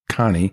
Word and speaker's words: CANEI: call it C A N E I, pronounced Connie, um, constant and never ending improvement.